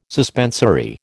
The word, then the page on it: suspensory: American English